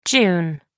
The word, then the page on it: jun: American English